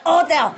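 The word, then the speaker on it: hôtel: And if it's an age, you just don't say age. You don't say hotel. You cut the ha ha ha. You cut the ha away. You say hotel!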